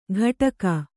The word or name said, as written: ಘಟಕ